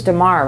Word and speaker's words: Damar: Please tell me I am pronouncing his name. It is Damar, right? – Mm-hmm.